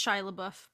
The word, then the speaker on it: Shia LaBeouf: Is that how we say his name? – It's Shia LaBeouf, but yes.